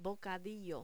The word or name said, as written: bocadillo